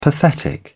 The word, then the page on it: pathetic: British English